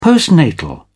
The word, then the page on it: postnatal: British English